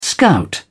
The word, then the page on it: scout: British English